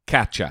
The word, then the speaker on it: Katja: Here's my attempt Katja. – Katja.